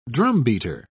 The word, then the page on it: drumbeater: British English